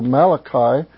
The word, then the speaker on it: Malachi: And in the Jewish scriptures, the Old Testament, what we call the Old Testament, all of the prophets, Isaiah through Malachi, are all in one section. And it's kind of interesting because Daniel is not included in them. But it's actually Isaiah through Malachi, or Malachi as it's pronounced.